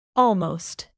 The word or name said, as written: almost